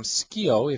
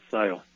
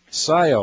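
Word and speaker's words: Scio: Our next caller is listening on the internet, calling from Skio, if I pronounce that right, in Oregon. Welcome to the program. – It's Sayo. – Sayo, all right.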